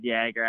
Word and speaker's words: Yager: Yeah, it is Yager. I know people, with the whole Jaromir Jagr and they see it as Yager, but so it is actually Yager, but people tend to say Yager.